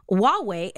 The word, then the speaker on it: Huawei: And then earlier in the week, Huawei, am I saying that right? Please, for the love of God, I hope I'm saying that like company right. It is this amazing cell phone. – It is that. That's how you say it.